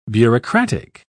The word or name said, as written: bureaucratic